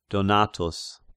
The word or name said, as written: Donatus